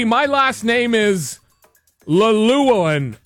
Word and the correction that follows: Llewellyn: So L-L-E-W-E-L-L-Y-N. I have two silent L's. Oh, my goodness. That's like the Trump card. Lelewin? Llewellyn. Llewellyn, right? I guess it would be. Am I right? Yes, Llewellyn.